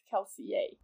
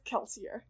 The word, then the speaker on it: Kelsier: So in World, Kelsier's name is like is supposed to be pronounced Kelsier. But Brandon has said, like, Brandon doesn't pronounce it this way. Brandon says Kelsier. And Brandon says, I don't say it the way people in the books would say it.